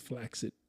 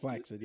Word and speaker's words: flaccid: It's actually pronounced flaccid. – Just flax it, yes.